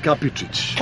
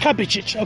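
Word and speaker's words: Kapičić: It's pronounced Kapicic. – Kapicic, okay, right.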